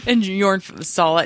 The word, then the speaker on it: Jorn: Jorn, fuck, you said it wrong. – Jorn. – No, like the guy had a shirt. You see the guy's shirt? – Jorn fucking Lande.